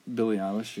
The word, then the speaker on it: Billie Eilish: Like when I found Billie Eilish, is that how you say it? – Billie Eilish, yeah.